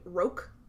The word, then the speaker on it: roque: he corners Danny in, like, this little area on the third floor, and he's coming at him with a roque mallet. Is that how it's pronounced? – Yeah. – Okay. And that's how the man in my audiobook pronounced it, okay, so good.